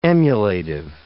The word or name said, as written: emulative